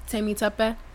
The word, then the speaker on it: Temitope: Temitope.